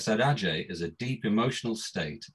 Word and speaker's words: saudade: And there's this concept in Brazilian music called, I'm not sure I can pronounce this word properly, but I think it's pronounced saudade, S-A-U-D-A-D-E.